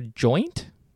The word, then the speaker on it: Joynt: Astra Joint? Joint? J-O-Y-N-T. How are you pronouncing that one, mate? – J-O-Y-N-T would be nothing else than joint. Joint.